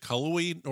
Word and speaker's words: Cullowhee: Is it Cullowhee, North Carolina? – No, that's right.